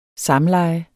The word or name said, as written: samleje